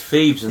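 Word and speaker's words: Phibes: And he say Fibes or Phoebes? – I think it's actually pronounced Phoebes. – I think it's Phoebes in the show.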